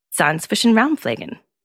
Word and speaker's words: Zahnzwischenraumpflegen: The correct pronunciation is Zahnzwischenraumpflegen.